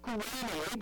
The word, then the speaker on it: Cyrene: Lucius was from Cyrene, and in Greek that word is pronounced Kurene, but that's where he was from.